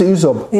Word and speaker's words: Ysop: Ysop, spricht man das so aus? Diese Anis Ysop. – Ja.